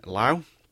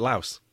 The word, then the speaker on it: Laos: And teams have now fly to Luang Prabang in Laos. See, everyone was saying it Laos.